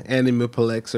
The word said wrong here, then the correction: Aniplex: or whatever. I said it all. – Yeah. – Aniplex. Yeah, that's how you say it.